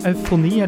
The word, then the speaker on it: Eufoni: Og eh, uttalte det riktig? – Jeg tror det er eh, ja, eufoni, eller au... Ja. – De bruker disse øynene våre vet uten å Faen. Så det er egentlig eufoni.